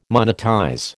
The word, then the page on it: monetize: American English